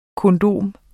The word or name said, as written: kondom